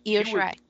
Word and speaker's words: Io Shirai: Io Shirai? Is that how you say it? – Yes. Yeah, Io Shirai.